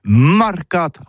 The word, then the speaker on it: marcat: Eh bien, disons à tous, mais le mercat de l'Uni ou le mercat des rebelles, mais en gascogne toulousain, c'est prononcé sur les marcat, avec un A. Marcat.